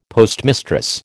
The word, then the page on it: postmistress: American English